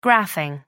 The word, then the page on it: graphing: American English